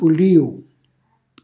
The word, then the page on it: pulliu: calabrese